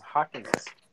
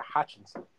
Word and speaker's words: Hockenson: It's Hockenson. The Hutchinson.